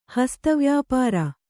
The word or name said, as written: ಹಸ್ತವ್ಯಾಪಾರ